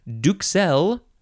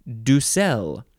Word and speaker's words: duxelles: Next is the single D-U-X word, which is pronounced do... Yeah, that's how you say it. Duxel or Ducel. So you can say it's spelled D-U-X-E-L-L-E-S. So you can pronounce a K sound or not.